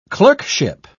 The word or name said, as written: clerkship